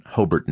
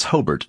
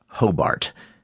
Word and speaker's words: Hobart: We met up with Don Dales at his home on Main Street in Hobart, New York. And that's Hobart, not Hobart. – Yes, we call it Hobart, with the accent on the first syllable.